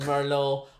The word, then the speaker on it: Merlot: It's because the tea is silent in Merlot.